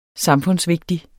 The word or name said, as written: samfundsvigtig